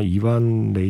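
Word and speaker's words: Evan: 노래를 부른 건 우리가 보통 에반 레이첼 우드라고 하는데 원래 발음은 아마 이반 레이첼 우드가 가까울 겁니다.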